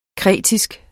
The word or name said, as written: kretensisk